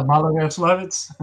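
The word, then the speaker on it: Maloyaroslavets: So now we're in the retreat, and we jump ahead to the Battle of, and I'll let you pronounce it since I have trouble with this particular Malaga Slavitz. – Thank you, sir. – What Malaya Yaroslavets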